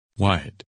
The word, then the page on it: Wyatt: English